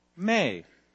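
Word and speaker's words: me (μή): It's an absolute negative form of another Greek word in bold, which is pronounced me, which means no or not.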